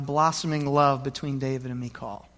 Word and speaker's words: Michal: Now, her name, it's been pronounced different ways. It's spelled M-I-C-H-A-L. Some people have called her Michael or Michal or Michal. I'm going to go with Michal if there's no objection.